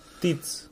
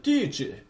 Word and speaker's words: Tite: Prea mulți pitici și tice, tice, cum are pronunța Tite scris așa, dar... Uh, Tiți. Da, da, exact. Dar brazilian a zis tice.